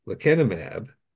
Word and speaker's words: lecanemab: is the mechanism actually by which lecanemab, I think is the way it's pronounced, leads to the first disease-modifying drug treatment in AD.